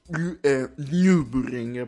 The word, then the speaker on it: Nürburgring: Si pronuncia così perché ha il Nullaut, oppure detto così volgarmente in italiano, i due puntini sulla U, quindi si pronuncia Nürburgring proprio così.